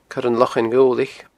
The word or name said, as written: coiranlochainghobhlaich